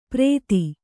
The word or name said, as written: ಪ್ರೇತಿ